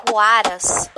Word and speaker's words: Huaraz: O nome dele se pronuncia Juaras.